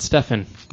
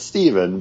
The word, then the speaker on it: Stephen: Stephen Strange, not Stefan. – Not Stefan. – I always thought of Stefan. – It spelled like it. – It's Stephen, but you always say Stefan.